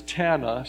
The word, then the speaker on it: Tana: There's a dear friend of mine that I've known for over 30 years, and she, her name is Tana. She always has said rhymes with Montana.